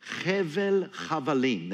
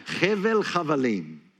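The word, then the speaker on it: Hevel Chavalim (הבל הבלים): The other phrase he uses, he calls it Hevel Chavalim. That's how you say it in Hebrew.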